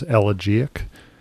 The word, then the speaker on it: elegiac: It even agrees with your pronunciation. It's got the first syllable is where the accent is elegiac.